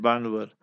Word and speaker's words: Bhanvad: So B-H-A-N-V-A-D, Banvard, but they pronounce it Banvard.